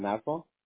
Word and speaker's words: Nassau: Nassau, like Nassau. N-A-S-S-U-A. Nassau County.